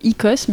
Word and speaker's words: Oikos: Icos, ça vient du mot grec Icos, mais qui s'écrit O-I-K-O-S et qui est à la racine des mots économie et écologie.